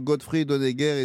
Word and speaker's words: Gottfried Honegger: D'abord, avant de décrire ça, un mot sur Gottfried Honegger et Sibylle Albers. Je prononce bien, là, ça va ? – Oui, tout à fait, c'est ça.